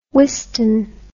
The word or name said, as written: Wystan